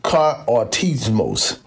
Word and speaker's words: katartismos: It's spelled K-A-T-A-R-T-I-S-M-O-S. Now, I pronounce it kartismos.